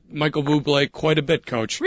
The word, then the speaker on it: Bublé: David Olson, who is Michael Bobbitt? – It's pronounced Bublé. – Bublé. – Bublé. – Oh, it's Bublé. I'm sorry. That's how good a guy is, Coach. You don't even say his name the way he is spelled.